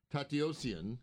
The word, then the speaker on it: Tateosian: Yes, they have a president, and it's Andrea Tateosian. I said it right this time. – Nailed it. – I did nail it.